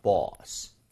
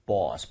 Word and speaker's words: boss: 자, 발음할 때 보스도 아니라 버스도 아니라 그 중간 정도가 되는데요. So 이런 느낌이에요. Boss. boss 보면 boss 요 정도 느낌을 빠르게 한 거라고 보시면 됩니다.